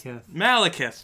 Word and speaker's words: Malekith: I can't actually pronounce Malekith.